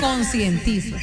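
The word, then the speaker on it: concientizo: Yo sé que no hay luz, me consentizo, que no hay luz. – ¿De qué? – Me consentizo. – Padre. Consentizo no se dice, se dice conscientizo.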